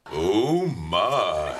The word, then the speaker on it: Omicron: Is it Omi or Oh my, how do you say it? – Oh my. – Thank you, Ensign.